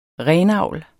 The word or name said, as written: renavl